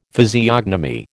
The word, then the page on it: physiognomy: American English